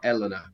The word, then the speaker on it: Elena: So would you say Elena or Elena? – No, in Italian it's Elena, which I know is a bit annoying, but yeah, we say Elena.